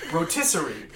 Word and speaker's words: rotisserie: This is a whole roastery chicken. – I'm sorry? – Roastery chicken. We're gonna roast Siri on the chicken. – Okay, cool. Rotisserie. – Rotisserie chicken. There you go. That's how you say it.